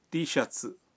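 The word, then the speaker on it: ٹی شرٹس (ティーシャツ): ٹی شرٹ کا جاپانی تلفظ ہے ٹی شرٹس.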